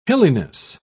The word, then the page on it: hilliness: British English